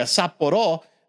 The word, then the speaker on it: Sapporo: And I remember I corrected him. I was like, yeah, I'll take a Sapporo. And he looked at me like I was some kind of asshole because I corrected him how to say it.